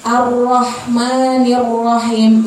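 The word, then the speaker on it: Ar-Rahmanirrahim (الرحمن الرحيم): Di ro-nya itu terdapat apa? Tasydid. Tasydid itu berarti Ar-Rahmanirrahim. Itu cara bacanya keseringan kalau kita dengar banyak orang yang salah tuh bacanya gini. Bismillahirrahmanirrahim. Bismillahirrahmanirrahim. Itu salah ya ibu-ibu, karena di ro-nya ada tasydid. Bismillahirrahmanirrahim.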